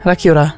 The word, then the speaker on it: Rakiura: What's the Māori pronunciation of Stewart Island? Is it Te Rāhui? – Is it? Or is it Rakiura? – Rakiura.